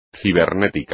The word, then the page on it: ciática: espagnol